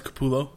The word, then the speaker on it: Capullo: I think it's Capullo.